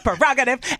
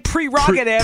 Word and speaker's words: prerogative: It's because he spelled it right but pronounced it wrong. – It's my prerogative. And then Britney redid it, and she did it wrong. – Did it wrong? – It's my prerogative. – Prerogative. – Say it right. – Prerogative. – I was so upset when I realized that word Dog. – Is nothing like I thought it was my whole life.